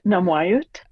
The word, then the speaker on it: Namwayut: Namwaywet. Am I saying that right? – Namwaiut. – Namwaywet, thank you.